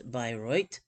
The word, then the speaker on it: Bayreuth: People have been describing the near religious experience of Bayreuth, pronounced Bayreuth, since its inauguration 150 years ago this summer, with the premiere of Wagner's four-opera epic, Der Ring des Nibelungen.